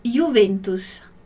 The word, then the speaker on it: Juventus: Mas aqui nesse caso, como é uma palavra que tem essa origem do latim, vai ter esse som de I, ok? Dillo chiaro, Juventus.